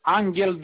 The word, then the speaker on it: Anghel: Angel Dorin, A-N-G-H-E-L. – Attendez, G-H-2-L? – Non, H-E-L. – L-L. – Non, E. U, E, U, je ne sais pas comment ça prononce là. – E. E, E, U, exact.